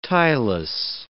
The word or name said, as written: tieless